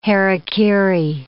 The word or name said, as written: hara-kiri